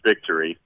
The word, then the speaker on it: VKTRY: First of all, the VKTRY is pronounced Victory. It's just one of those funky ways to spell it and present it.